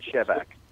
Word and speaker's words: Shevack: I pronounce it Shevack.